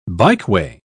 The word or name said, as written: bikeway